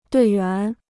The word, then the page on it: 队员: duì yuán